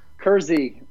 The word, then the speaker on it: Kersey: Is that the correct way to pronounce it, Kersey? – Kersey. Jersey with a K is how I...